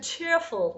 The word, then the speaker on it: cheerful: One last thing, actually going back to cheerful, which we talked about earlier, the last syllable of that, the full, is the open capital cookie O sound, and not the schwa, right? – Technically, yes. The F-U-L suffix is most often realized, especially when it's drawn out, as flying U, open O, cheerful.